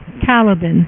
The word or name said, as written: Caliban